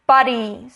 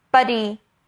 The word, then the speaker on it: body: body 的发音呢，不是发 buddy，buddy，buddy 跟 body 是有差的哦，大家可以试试看。 Buddy，第一个 buddy 呢是好朋友的意思。